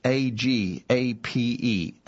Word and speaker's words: agape: Uh, spelled with English letters, it's A G A P E, agape. Uh, that that's the the Greek word, uh, but it it is the it is the kind of love the highest sort of love.